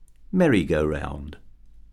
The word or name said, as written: merry-go-round